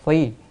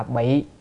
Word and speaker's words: phi: chữ này đọc là phi, đem quần áo mặc vào người, không đọc là bị mà đọc là phi, thì cùng một nghĩa với chữ phi đó.